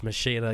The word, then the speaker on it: Ex Machina: And if you think it's pronounced Machina, you're a fucking idiot. It's Machina.